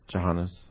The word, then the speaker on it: Jhanas: I was thinking, like, so, in, like, classic Buddhism, you know, there's the eight dhanas. – The eight? – Dhanas. Am I pronouncing it wrong? Dhanas. Jhanas. – Jhanas? – Jhanas. – Oh, sorry. – Jhanas. – Jhanas. – Oh, okay, Jhanas.